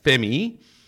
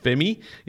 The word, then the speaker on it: F3MIII: coming up on number 37 is an artist known as Femi, and it's spelled F3MIII, and it's known as Femi.